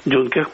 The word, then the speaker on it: Дюнкерк: Мне понравился «Дюнкерк» по-русски, правильно говорить, Кристофера Нолана.